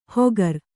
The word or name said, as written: ಹೊಗರ್